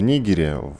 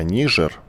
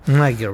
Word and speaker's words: Нигер: Давайте только коротко напомним, что в Нигере, в такой стране, которая, может, и не Нигер на самом деле, а Нижер, правильно произносить, как написала. – Нагер вообще-то по-английски. Скажи это Маше Бароновой, пожалуйста.